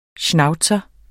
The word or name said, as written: schnauzer